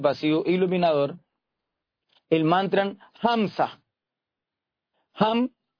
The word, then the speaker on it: Ham: Cuando su merced inhale, vamos a pronunciar la mitad del mantra, que es H-A-M, o sea, Ham.